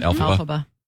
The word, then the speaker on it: Elphaba: The Elphaba, is that how you say it? – Elphaba.